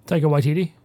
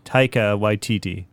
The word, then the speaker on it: Taika Waititi: Taika Waititi? – Taika Waititi.